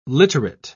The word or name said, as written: literate